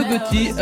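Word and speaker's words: Yo Gotti: Yougoti, A Boogie With Dawdy et Ty Dolla Sign, ce sont les trois artistes et ils signent avec Like That.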